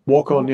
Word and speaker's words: Waukon: And they lived not far away in the same town of Waukon. Is that how you pronounce it? – Waukon, I think so. Waukon, yeah.